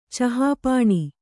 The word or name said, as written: ಚಹಾಪಾಣಿ